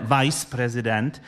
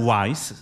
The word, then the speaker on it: vice president: Ale někteří lidé, když se to píše, tak oni si tam pletou při výslovnosti, přes anglicky se řekne vice president. Oni si to pletou se slovem wise, kde je dvojité V, což znamená moudrý. Takže potom vice president podle nich je moudrý prezident, kdežto prezident je jenom prezident.